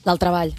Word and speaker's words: carrer del Treball: En la calle Treball. – Del Treball. – En la calle del Treball. – El carrer del Treball.